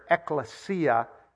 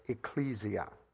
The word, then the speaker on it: ekklesia: And it comes from the Greek word ekklesia. We pronounce it in English, ecclesia.